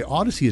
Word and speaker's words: Audacy: By the way, Odyssey is spelled A-U-D-A-C-Y.